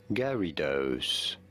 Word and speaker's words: Gyarados: So, the official pronunciation is as Gyarados. Somewhat like Gary and Dose.